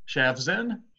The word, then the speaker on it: Shavzin: How do you say your last name? Shavzin? – Shavzin, just like it sounds.